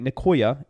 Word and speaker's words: Nicoya: I hope I'm saying that right. Nicoya, N-I-C-O-Y-A Peninsula.